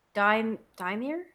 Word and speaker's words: Volodymyr: Man, I can't even pronounce his name. It's like Volodymyr? – Volodymyr Zelensky. – Oh, there you go.